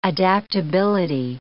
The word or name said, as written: adaptability